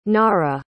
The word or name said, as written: nara